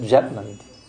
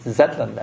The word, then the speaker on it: Zetland: Prabhupada is Bengali, he doesn't say the Z sound, so he said, he pronounced it Jetland. Lord Jetland, but it's Zetland actually.